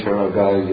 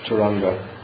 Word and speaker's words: Turanga: And then when you say Turanga, you have to stick the N in there for. Is a pronunciation. Tura is another name for a horse. Nri Tura. Turaga. I mean, Turanga.